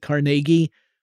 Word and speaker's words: Carnegie: I always pronounce it Carnegie, because that's how Carnegie pronounced it, but Carnegie Mellon.